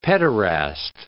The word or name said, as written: pederast